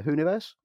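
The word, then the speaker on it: Whoniverse: the Hooniverse. – The Hooniverse, yep.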